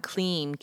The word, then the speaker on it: klim: So let me tell you about the seed sound kleem, K-L-I-M, pronounced K-L-E-E-M.